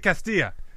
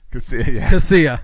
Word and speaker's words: Casilla: Alexi Castilla. – Casilla.